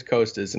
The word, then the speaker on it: Costas: That's one I've had to really work on because I want to say Costas, but it is Costas. And I feel like I've gotten better at that. – Right. But that's literally in the pronunciation guide. It just says coast, like as in the coastline.